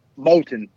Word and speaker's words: Moulton: Did I say your last name right? Moulton? – It's Moulton.